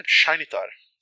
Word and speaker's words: Shintar: Shinitar. – Shinitar. – Se escreve Shintar, mas no livro ele diz que se pronuncia Shinitar.